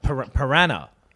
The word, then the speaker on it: piranha: I've heard you pronounce piranha. – Piranha. – Differently piranha. Every time. Piranha. – Yeah. – Piranha. – It's piranha. piranha sounds a bit posh, don't you think? – That is how it's pronounced.